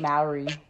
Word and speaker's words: Mowry: How do you really pronounce it? Maori or Maori? – I say Maori. – Maori. Yeah, it's Tia Maori.